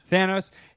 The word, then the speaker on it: Thanos: I don't know how to pronounce it. Thanos, right? – Thanos.